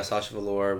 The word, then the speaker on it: Sasha Velour: Yeah, Sasha Velour, winner of season nine.